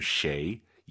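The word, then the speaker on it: 谁: 쉐이, 쉐이 해도 알아듣지만 정확한 발음 쉐이, 입을 가로로 쫙 찢으면서 쉐이 발음하시기 바랍니다.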